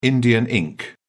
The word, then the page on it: Indian ink: British English